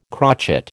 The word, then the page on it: crotchet: American English